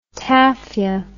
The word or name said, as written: taffia